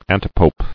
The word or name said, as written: Antipope